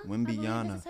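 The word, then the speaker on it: Wembanyama: Wembiana.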